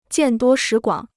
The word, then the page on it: 见多识广: jiàn duō shí guǎng